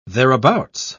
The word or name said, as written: thereabouts